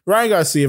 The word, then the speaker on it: Ryan Garcia: But the main event, Ryan Garcia, I think I, I think I said it right the first time. Ryan Garcia versus Luke Campbell.